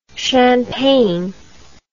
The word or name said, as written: champaigne